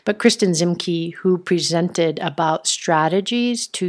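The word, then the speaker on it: Kristin Ziemke: And in fact, I have a new girl crush because this Kristen Zimke, I think is how you say her name, Kristen, K-R-I-S-T-I-N, Zimke, Z-I-E-M-K-E.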